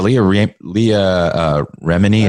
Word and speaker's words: Leah Remini: Yeah, Leah Remini, is that how you say it? – Yeah.